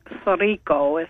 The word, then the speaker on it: Sorriso: It's Italian, so it's actually like Sorrico is how it's pronounced.